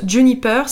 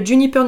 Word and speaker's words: JNPR: Ensuite, une autre marque dont j'entends beaucoup parler, je pense que ça se prononce Juniper, ça s'écrit JNPR, une marque de spiritueux premium, je n'ai rien inventé, c'est eux qui le disent.